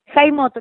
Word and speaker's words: Jaimot: Bueno, primero, eh, ¿es con J? ¿Tu apellido es Jaimot o Jaimot? – Jaimot, lo pronunciaste bien.